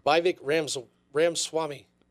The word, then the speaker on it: Ramaswamy: Vivek Ramaswamy.